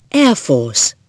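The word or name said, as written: air force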